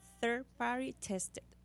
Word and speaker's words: third party tested: Cuando usted consiga un medicamento o un suplemento over the counter, mire siempre que diga third party tested. Third, T-H-I-R-D, de tercero, party de P-A-R-T-Y y tested de probado, de T-E-S-T-E-D. Lo dije bien, Flori. – Sí, tested. Bien. – Third party tested. Bien fácil.